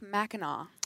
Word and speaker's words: Mackinac: Help me with that pronunciation that I butchered on the Line 5 story. – Pronounced Mackinac. – Mackinac Straits, the Straits of Mackinac.